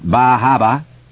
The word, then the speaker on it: Bar Harbor: Camden, Rockland, Bar Harbor, pronounced Bahaba, Wiscasset, Bath, Portland, Ogunquit, the Berwicks.